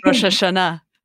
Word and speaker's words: Rosh Hashanah: And then being able to celebrate like Eid, like Ramadan or Rosh Hashanah, I don't know how to pronounce it. – Rosh Hashanah.